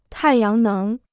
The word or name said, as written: 太阳能